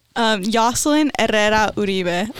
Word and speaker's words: Yaslyn Herrera Uribe: Yaslyn Herrera Uribe. I know it's like the rolling with the R's, and it's just like my thing with it.